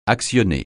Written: actionner

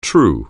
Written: true

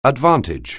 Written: advantage

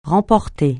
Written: remporter

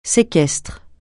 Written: séquestre

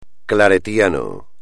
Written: claretiano

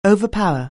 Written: overpower

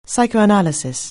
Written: psychoanalysis

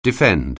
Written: defend

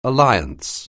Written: alliance

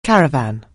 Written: caravan

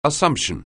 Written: Assumption

